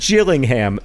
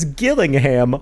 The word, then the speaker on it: Gillingham: This is wrong. It is pronounced Gillingham, soft G like jam, and that's important because Gillingham, hard G like girl, is a different town in England.